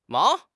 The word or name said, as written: mao2